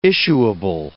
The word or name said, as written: issuable